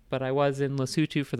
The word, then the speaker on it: Lesotho: I was born in Lesotho, which most people think is pronounced Lesotho, but it's not.